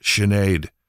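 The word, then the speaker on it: Sinéad: It's pronounced Sinead. Well, that's not correct. I'm sorry. That's her name. That's how she says it. No, that's not correct. – Right. – Yeah. That pronunciation doesn't work for that spelling.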